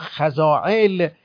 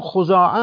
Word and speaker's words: خزاعه: اخیراً خزاعه در تلفظ عربهای جنوب ایران و عراق خزاعل شناخته شدن در صورتی که صحیحش همون خزاعه است.